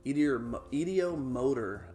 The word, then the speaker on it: ideomotor: Well, you asking that question, Kaylee, there's a theory, and I've been pronouncing it wrong for the longest time, but I know what I'm trying to talk about. It's called the idiometer. ideomotor effect. There it is. The ideomotor effect.